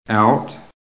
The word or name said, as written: OUT